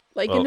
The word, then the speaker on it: lichen: The hard shell, also mispronounced as chitin. – Ah. – Much like lichen is also possibly lichen sometimes.